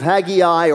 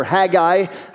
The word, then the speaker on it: Haggai: book of Haggai or Haggai. It's pronounced both ways, you can pronounce it that way.